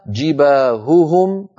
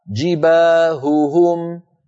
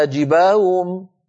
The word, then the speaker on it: جباههم: هوهم، جباههم، ولا نقول مثلا فتكوى بها جباهم، اسمعوني هكذا جباهم، وكأن الأصوات ضاعت وكأن الهاءات صارتا همزات مسهلات، جباهم، لا يصح هذا أبدا، بل لابد لنا من بيان صوت الهاء الأولى من صوت الهاء الثانية، وأن ننطق الهاء صافية ليست مشوبة بصوت آخر حلقي، هكذا.